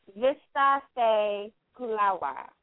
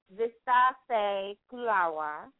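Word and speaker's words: vistara se khula hua: And that in Hindi is Vista Se Kulawa. And I'll say it again, and I'm going to spell it. It's Vista Se Kulawa.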